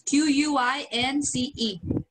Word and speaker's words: quince: So Sorry, Rio is Qu- Queens or Quincy. I don't know how to pronounce it, but it's Q U I N C E.